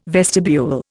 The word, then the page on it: vestibule: Indian